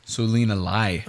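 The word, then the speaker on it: Selena Lie: Selena Lai. – Okay, thank you. – L-I-E.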